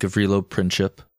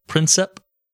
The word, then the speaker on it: Gavrilo Princip: And while we're on the subject of getting out of character here, out of story, you'll notice I've been saying Gavrilo Princip because I looked it up. I look up a lot of pronunciations we run into while reading. And that's what I got from people that actually speak the language. I know it's commonly known as Princip to most of us Westerners, but I had to stick with something, so I'm gonna go with what the people native tongue speak.